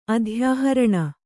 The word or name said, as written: ಅಧ್ಯಾಹರಣ